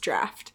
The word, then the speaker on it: draught: I pronounced that word Draught. And then Tyler texted me and was like, it's pronounced Draft.